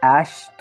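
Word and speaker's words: Æ: Que algunas fuentes dicen que se pronunciaría como un Sasha 12 pues dicen la, esa la A con la E ahí medio rara, se pronuncia Ash, pues yo digo, va a decir Sasha, ¿no?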